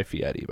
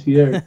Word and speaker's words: Fieri: I just want to clear the record to say that his name is Guy Fieri, by the way. – Is it Fieri? I don't know how to pronounce yes. – it. – Yes. – Fieri? I call him Flavortown. – Flavortown. – Well, that's the Mandela effect, because it was always Fieri.